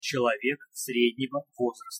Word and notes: celovek srednego vozrasta: Russian

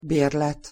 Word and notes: berlet: Hungarian

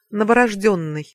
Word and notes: novorozdennyj: Russian

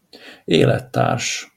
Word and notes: elettars: Hungarian

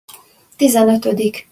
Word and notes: tizenotodik: Hungarian

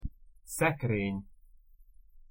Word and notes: szekreny: Hungarian

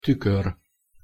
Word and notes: tukor: Hungarian

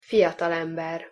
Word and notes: fiatalember: Hungarian

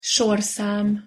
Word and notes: sorszam: Hungarian

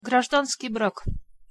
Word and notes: grazdanskij brak: Russian

